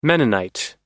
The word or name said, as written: mennonite